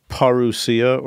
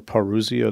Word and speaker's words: parousia: Well, the first one is about the imminent parousia or parousia. It's pronounced both ways, but it has to do with the presence or the arrival of someone.